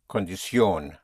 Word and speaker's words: condición: Yes, but you hit the ending. Condición.